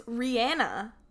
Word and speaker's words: Rihanna: Are you saying Rihanna? – That's how you're supposed to say her name. – Is it? – Yeah. It's Rihanna.